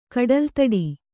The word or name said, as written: ಕಡಲ್ತಡಿ